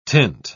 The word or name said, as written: tint